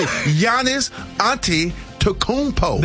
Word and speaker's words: Giannis Antetokounmpo: Well, the Milwaukee Bucks, they are back in the strong consideration of trading Giannis Antetokounmpo. Did I say it right? – Did he say it? – Let me see. – Giannis Antetokounmpo.